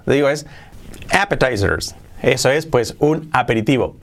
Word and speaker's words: appetizers: Eh, appetizers, no, appetizers, con acento en la appetizers. Appetizers sería pues un pequeño pues aperitivo, entrante, appetizers. Appetizers, con, la, con, la, con el acento en la A.